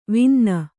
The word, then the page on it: ವಿನ್ನ: vinna